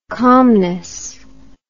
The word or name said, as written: calmness